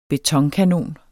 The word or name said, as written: BETONKANON